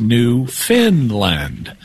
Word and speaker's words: Newfoundland: It's spelled Newfoundland, but it's pronounced Newfinland, even though it's not located in Scandinavia.